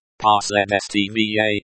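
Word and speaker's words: Posledstvie (последствие): It is pronounced like Posledstvie, with a slight stress on the second syllable.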